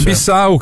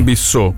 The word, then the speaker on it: Bissau: Ecco, tra l'altro è Bissau che si pronuncia così, non Bissau, quindi ma infatti mi torna di più, perché grazie a Francesco Benvenuti.